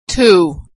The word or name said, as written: too